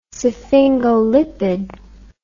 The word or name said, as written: sphingolipid